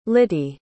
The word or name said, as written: littie